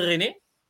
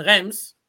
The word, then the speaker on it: Reims: Llega a la primera división, ficha para el Stade René que muchos dicen Reims. Por eso, por eso, por, eso yo digo que en los clubes de Francia hay que leer cómo se escribe porque es muy difícil.